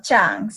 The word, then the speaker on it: Trang: So my name is Trang. It's spelled like T-R-A-N-G, but the correct way to pronounce it is Trang. So you say it's C-H, Trang.